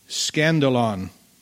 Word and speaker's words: skandalon: Jesus is telling his disciples that even though offenses, and that word offense comes from a Greek word, it's spelled like this, S-K-A-N-D-A-L-O-N, scandalon. What does that sound like? Scandal. That's where we get the word scandal from.